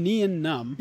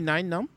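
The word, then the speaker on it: Nien Nunb: Now, I want to point out that I just said it correctly for the first time in my life. Only found one of your favorite characters. – Dude, we've been calling him Nian Num.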